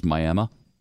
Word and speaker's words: Miami: There's a place that's spelled like Miami, but it's pronounced apparently in Texas, Miami.